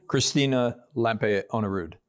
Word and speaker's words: Christina Lampe-Onnerud: I'm Alan Murray, and I'm super excited today to be here with my friend, Christina Lampe Onerud.